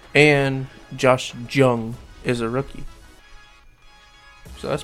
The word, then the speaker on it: Jung: maybe, depends on how you look at it, versus Josh Jung. It's not a J, it's pronounced with a Y. Or it's not pronounced as a J, it's pronounced as a Y, I'm pretty sure.